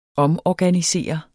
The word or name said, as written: omorganisere